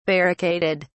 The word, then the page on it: barricaded: British English